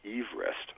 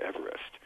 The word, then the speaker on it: Everest: because his actual family name was pronounced Everest, not Everest. But you see, it wasn't Everest that caught people's attention as much as the land that lay beyond Everest.